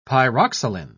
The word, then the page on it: pyroxyline: British English